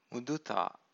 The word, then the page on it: muduta: Pali